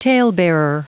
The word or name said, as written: talebearer